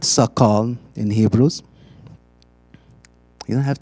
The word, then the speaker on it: sakal: In Hebrews, it's spelled like this: sakal, S-A-K-A-L, sakal.